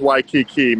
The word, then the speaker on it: Waikiki: It's Waikiki. It's pronounced Waikiki, man. You better start getting that right because the belt kind of lives here now.